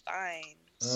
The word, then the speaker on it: Fiennes: Number six, we have Joseph and Ralph Fiennes. – Nice. – Nice. – Who? – She even pronounced it correctly.